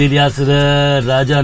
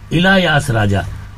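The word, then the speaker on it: इलायास राजा (இளையராஜா): इलायास या प्रोनाउंसिएशन के लिए, क्योंकि साउथ के हैं वो संगीतकार, कहते हैं it is इलायास राजा, not इलियास राजा।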